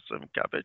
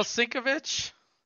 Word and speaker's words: Sienkiewicz: No, the artists are all listed as, I can never pronounce his name, Sienkiewicz. Is that how you pronounce it? Bill Sienkiewicz. – Bill Sienkiewicz? – Yeah.